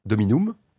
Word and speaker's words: Dominum: Le U suivi d'un M en revanche, c'est bien Dominum. Ça prononce comme le U. Là, c'est Dominum.